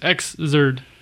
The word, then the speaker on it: Xrd: That's how it's pronounced, Xrd. – Okay. – Or Xrd, I guess, because you're supposed to pronounce the X as X, and then Erd. This is like third.